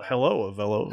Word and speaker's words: Avelo: Hello, Avelo. That's if you're wondering how it's pronounced.